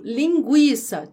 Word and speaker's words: linguiça: Outro exemplo, linguiça tinha trema, não tem mais. Linguiça.